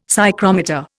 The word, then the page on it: psychrometer: Indian